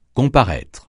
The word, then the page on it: comparaître: francais